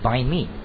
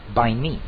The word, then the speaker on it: Banh mi: Banh mi. Right? – Yes. – Yeah. Did I say it right? Bind me. – Yes. Yeah. Right.